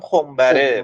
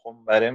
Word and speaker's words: خمبره: تلفظ پیشنهادی تو بگو فکر میکنم خمبره مثلا به توی آره قطعا به زم نظامی توی شعراش با همین وزن تو وزن خمبره میاره